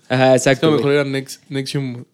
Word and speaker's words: NXIVM: Esta chava eh, es culpable de, todas esas madres por, colaborar una madre que se llama Nexium, que se escribe N-X-I-V-M, así como, ya sabes, como...